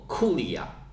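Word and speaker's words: curia: 其实最开始呢是分成十个区，每一个区呢叫做族区，在拉丁语里面读作库里亚。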